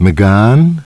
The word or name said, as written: McGann